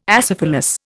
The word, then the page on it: acephalous: Indian